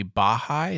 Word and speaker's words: Baha'i: So he has these conversations all the time, and he's supposedly Baha'i.